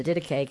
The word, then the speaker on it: Didache: the Didache. How do you say it? The Didache. Yes.